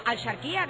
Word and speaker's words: Axarquía: Intentamos pronunciarlo de la manera antigua al charquía, que era como se decía antes.